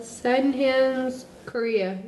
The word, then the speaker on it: Sydenham's chorea: Sydenham's chorea? Sydenham's chorea minor? Chorea? – Sydenham's chorea. Yeah. – All right. – How I would say it.